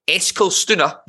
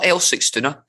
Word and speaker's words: Eskilstuna: And I really fancy AFC Eskilstuna, not Eskilstuna, because that's not two separate teams. I've just been pronouncing it wrong for 2 years and was recently corrected.